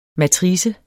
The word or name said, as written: matrice